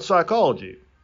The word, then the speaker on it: psychology: What about psychology? – The S is silent there. No, the P is silent, if I'm not mistaken.